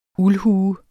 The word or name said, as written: uldhue